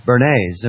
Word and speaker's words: Bernays: Although, the article continues here, the title could also be held by Edward Bernays. And Bernays, by the way, if you don't know, is spelled B-E-R-N-A-Y-S. It's not like a Bernays sauce. B-E-R-N-A-Y-S, Edward.